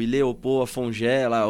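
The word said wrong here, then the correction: Ilê Opô Afonjá: o, o Ile... Opo, Afonjá, né? Eu até pronunciei errado aqui, perdão, porque é, é na linguagem deles, né?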